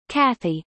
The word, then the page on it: cathy: American English